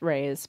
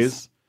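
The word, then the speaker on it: Reyes: They had a historic water source that they would use at this point, Rees National Seashore. How does that pronounce? Rays? Rees? What is that? – I think it's Rays. Point, Point Rays. – Rays.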